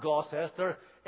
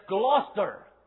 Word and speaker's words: Gloucester: Anybody who knows anything about England knows it's not Gloucester, it's Gloucester. because this is what you call a syncope, because the middle syllable has been just glossed over so that you can barely notice it there at all. And so what they do, they put the emphasis on the first and the last syllables, and the middle one disappears.